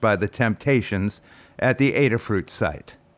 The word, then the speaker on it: Adafruit: The place is called Adafruit. I'll spell it ADA. That's ADA, ADA, Alpha Delta Alpha. Adafruit, like raspberries are a fruit. Adafruit.